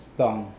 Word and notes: poang: Mokilese

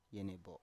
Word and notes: yenebo: Biak

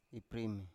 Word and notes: iprime: Biak

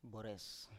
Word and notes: bores: Biak

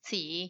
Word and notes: tsii: Macuiltianguis

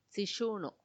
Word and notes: tsixunu: Macuiltianguis

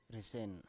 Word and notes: risen: Biak

